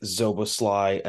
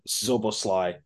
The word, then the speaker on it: Szoboszlai: Today we are going to start out with Dominik Szoboszlai announced at Liverpool officially this past week. Everybody be very impressed that I got that pronunciation correct. First time, Szoboszlai. I'm probably still somehow messing it up.